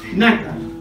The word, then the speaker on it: nácar: con un elemento que va solidificando y cicatrizando la herida que se llama nácar, con acento en la A, nácar.